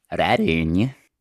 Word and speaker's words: Redenyi: Der wird geschrieben R-E-D-E-N-Y-I. Und ich habe das Reini einfach mal selber sagen lassen. Wie spricht man den aus? Reini.